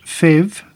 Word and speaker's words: PHEV: FEV, PHEV, crikey, lots of acronyms here, commonly pronounced FEV, there you go, promises the advantages of petrol and electric drivetrains.